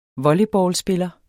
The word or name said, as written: volleyballspiller